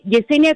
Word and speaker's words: Yesenia: Yesenia con Y y una S es nombre gitano, significa regalada por Dios o flor mm.